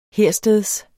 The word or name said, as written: hersteds